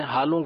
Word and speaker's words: حالوں: حالوں کے بیج، ایک بعض لوگ اس کو کچھ تلفظ درست نہیں ان کو سمجھ پاتے، آلو نہیں ہے، یہ ہا سے ہے، ہا الف ہا،